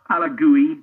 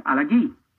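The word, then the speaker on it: El Alagui: He said, it's Farid El Alagoui. So not Alagoui, not Alagoui, Alagoui.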